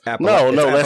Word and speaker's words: Appalachian: We're the only people that pronounce it that way. The rest of the country pronounces it Appalachian dialect. Yeah, right, like wife and sister is the same word. – Yeah, it's Appalachian.